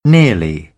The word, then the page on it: nearly: British English; ə